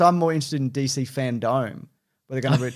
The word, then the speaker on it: FanDome: No, it's Fandome. It's like the dome.